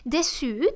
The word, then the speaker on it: déçu: So we use déçu, which is spelled D-E acute, C with a French cédille and U. And an E if you are referring to a female who is disappointed, déçu.